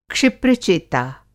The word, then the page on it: क्षिप्र-चेता: Hindi